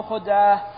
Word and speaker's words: خدعة: خدعة. – نعم. قوله باب الحرب خدعة تنطق بفتح الخاء وضمها، والفتح أفصح وأولى.